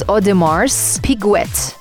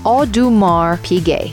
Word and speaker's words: Audemars Piguet: It's written as Audemars Piguet, but the way you pronounce it is Audemars Piguet, without pronouncing the S and without pronouncing the T in the end.